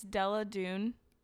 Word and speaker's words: Della Dune: Oh, it's Della Dune. – Della Dune? – Yeah. – How do you spell that? – D-E-L-L-A.